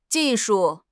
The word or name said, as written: ji4 shu4